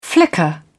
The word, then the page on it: flicker: British English